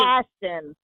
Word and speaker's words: Boston: You said it wrong. – Isn't that how you people say it? – Not Boston. You said you had too much emphasis. Your O turned into an A.